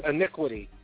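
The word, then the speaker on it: Eniqueity: It's Inquiry. It's E-N-I. Q-U-E-I-T-Y.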